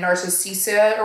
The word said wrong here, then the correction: Nicasia: or whatever her name is. – Nicasia.